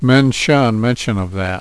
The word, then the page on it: mention: menshun